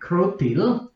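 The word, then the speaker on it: Kroutil: Well, my name is Czech, and from there it would be pronounced somehow like Krutil.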